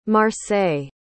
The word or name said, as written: marseille